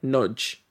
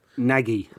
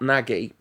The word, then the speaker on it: Nagy: In fact, now I'm saying it, I'm slightly doubting that his name's Adam, but his name is written Nagy, and you'll hear his name pronounced a lot of different ways. But I have it on good authority that it's like Nudge. That's how you're supposed to pronounce it. – Nagy. – Yeah, it's not Nagy, but he's good, apparently.